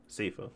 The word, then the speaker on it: Sifu: Um, but they, but it's still like I said, seafood. Uh, he says, is it how you say it? – Seafood? It's seafood. – Sifu. Sifu looks really good.